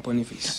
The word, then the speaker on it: Boniface: Yeah, I think I've said his name right. – Boniface.